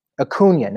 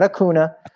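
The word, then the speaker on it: Acuña: So for that reason, I'm going to bench Juan Soto, and I'm going to start Ronald Acuna Jr. Acuna, not Acuna, Acuna. And you know how I learned to pronounce that? Bryce Harper. Bryce Harper taught me that one.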